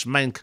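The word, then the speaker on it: Meink: Now, part of Tony Make's speech, Tony Make, the Secretary of the Space Force, had to look up how to pronounce his name. Apparently it's Mank to rhyme with skank.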